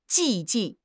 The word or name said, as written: 寂静